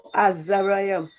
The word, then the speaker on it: Azariah: Then Daniel went in the house and made the thing known to Hazael, Mishael, and Azariah. You'll have to help me because this pronunciation is not... – Azariah. – Oh, Azariah and his companion.